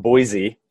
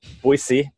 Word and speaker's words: Boise: Notice the pronunciation. It's not Boise. Boise, Idaho.